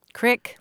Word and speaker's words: crick: Crick, like right? – in your neck? – No, crick as in creek, C-R-E-E-K, and it's a regional pronunciation.